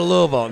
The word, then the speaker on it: Louisville: Louisville, yeah.